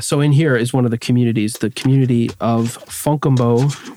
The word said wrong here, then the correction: Fontgombault: Here's Fontgombault.